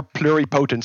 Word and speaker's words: pluripotent: They are pluripotent stem cells.